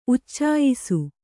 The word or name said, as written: ಉಚ್ಚಾಯಿಸು